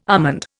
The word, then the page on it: almond: Indian